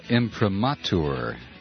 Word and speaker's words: imprimatur: Robert emails in that he's always pronounced it imprimatur.